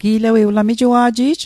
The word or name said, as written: gi'lewei